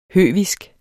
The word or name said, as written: høvisk